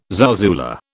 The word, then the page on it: zarzuela: British English